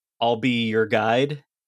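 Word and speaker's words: Ahlbi Ur'gaid: There's a person in the first case who has like kind of a Middle Eastern name, which is spelled AHLBIUR'GAID, but it's pronounced "I'll be your guide."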